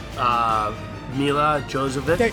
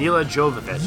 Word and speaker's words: Jovovich: Mila Jovovich. I always butcher it. Mila Jovovich.